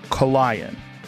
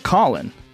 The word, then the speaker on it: Colion: C-O-L-I-O-N. Kolion. Seems too much like Colin. But it's not Colin.